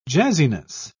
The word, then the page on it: jazziness: British English